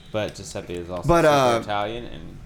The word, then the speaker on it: Giuseppe: Yeah, you said it wrong. – Giuseppe? – Oh, no, it's Giuseppe. – Giuseppe is like a nickname. – Yeah. – But it's Giuseppe.